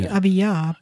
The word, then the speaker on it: Abijah: Is that close to pronounce it? Abijah? – Probably Abiyah. – Abiyah. Oh, yeah, right.